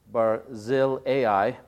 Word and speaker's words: Barzillai: and Barzali, let me pronounce that a little better, let's see, Barzillai,